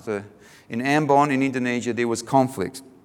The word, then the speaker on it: Ambon: Ambon is how we pronounce it in Indonesia.